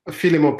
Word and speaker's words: Philemon: Philemon Padano is right.